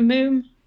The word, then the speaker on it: Mum: I like, do you know Moom? – No. – Like it's spelled Mum, M-U-M.